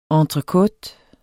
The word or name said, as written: ENTRECOTEN